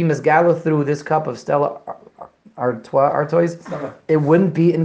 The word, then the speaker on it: Stella: Stella, that's how you pronounce that? – Stella. – We'll take your word for it.